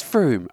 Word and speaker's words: Frome: Am I pronouncing that right? I want to say Frome. It's not Frome, isn't it? – Perfectionist, yeah. Frome, in some sense. Oh, it is Frome.